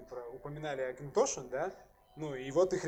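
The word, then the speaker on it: Auchentoshan: Как это правильно вообще произносится, потому что Да, ты застал непонятно. момент, когда они переходили с Окин Тошин на Акин Тошин.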